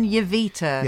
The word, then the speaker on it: Jowita: okay, next it is Hamza and Yurita's I've said her name wrong, haven't I? Hamza and Jowita.